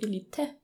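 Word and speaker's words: Élite: Elite? Mit E? – Elite? Ja, weil es ein Akzent am E. – Okay. – Also es ist zwar Spanisch, klar, ja, aber.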